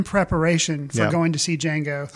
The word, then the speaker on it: Django: For some reason, even though they bring it out in the movie that it is Django, the D is silent, I constantly, when talking about it with my wife, I'll go like, Django.